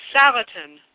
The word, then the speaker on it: Salatin: Salatin. Joel Salatin. – Salatin, yep.